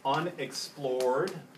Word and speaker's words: unexplor'd: So notice that the rhyme here is unexplor'd, rhyming with, Lord. – Yeah, or let's get the rhythm, reject a lord.